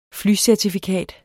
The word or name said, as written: FLYCERTIFIKAT